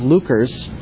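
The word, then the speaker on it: Leuchars: Lucres given him shortly after. I'm waiting to see if Simon's going to correct my pronunciation. Yeah. Oh, that's correct. Well, sometimes it's better to be lucky than good.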